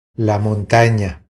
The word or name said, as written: La montana